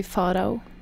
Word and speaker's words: Pharao: In Norwegian, it would be Faro.